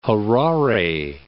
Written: Harare

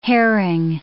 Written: herring

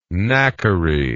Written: knackery

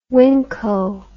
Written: wingco

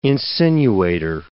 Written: insinuator

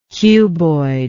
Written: cuboid

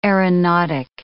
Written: aeronautic

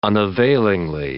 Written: unavailingly

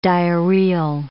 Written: diarrheal

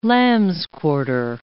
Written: lamb's-quarter

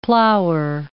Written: plower

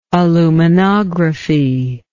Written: aluminographic

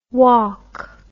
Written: waulk

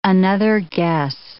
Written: another guess

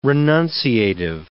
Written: renunciative

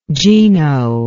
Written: gino

